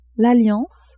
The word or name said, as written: alliance